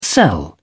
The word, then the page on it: sell: British English